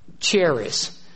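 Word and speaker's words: charis (χάρις): Now it's spelled C-H-A-R-I-S. It looks like charis, but just like the choir or chorale, sometimes the H is silent, and we pronounce it like a K. You pronounce it like a K, and the I-S, we would say is, but it's in Greek it's is. So it's charis or charis.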